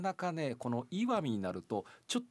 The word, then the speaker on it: 石見: 石に見ると書いてこれで岩見と読むんですが、